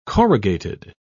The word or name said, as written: corrugated